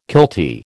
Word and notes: kiltie: American English

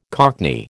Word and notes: cockney: American English

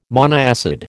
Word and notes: monoacid: American English

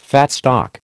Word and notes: fat stock: American English

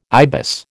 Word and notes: ibis: American English

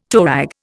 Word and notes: toerag: Indian

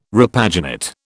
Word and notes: repaginate: British English

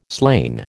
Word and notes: slain: American English

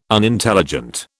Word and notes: Unintelligent: British English

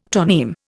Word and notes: toneme: Indian

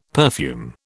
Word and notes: perfume: British English

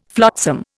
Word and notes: flotsam: Indian

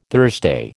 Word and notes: Thursday: American English

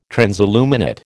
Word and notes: transilluminate: American English

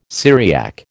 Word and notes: Syriac: American English

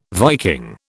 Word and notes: Viking: British English

